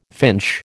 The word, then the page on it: finch: American English